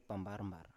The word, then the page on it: pambar-mbar: Biak